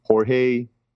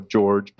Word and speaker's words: Jorge: But if they want to shoot me an email as well, it's Jorge or George, but it's spelled Jorge, Man, see, you screwed me up on that too.